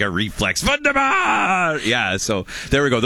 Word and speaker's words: Wunderbar: So Curtis picked Wonder Bar, and I'm like, "You're not saying it right. It's Wunderbar!" And they both immediately reflexively yelled Wunderbar!